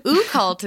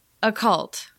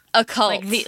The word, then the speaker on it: occult: Wait, how do you pronounce it? – Occult. – Occult. – Like the occult, yeah. – O-C-C-U-L-T. Yeah, okay, all right.